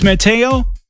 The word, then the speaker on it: Mateo: And did you say your name's Mateo? – Yes, M-A-T-E-O.